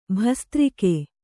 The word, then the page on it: ಭಸ್ತ್ರಿಕೆ: bhastrike